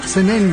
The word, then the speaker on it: Zenén: También está por ahí Zenén, mejor dicho, lleva acento en la última, Zenén, Urso, Godeleva, Julita, María de Jesús, María Vicenta, Manes, Sergio, Braulio, y hasta ahí nomás llega los santos de este día.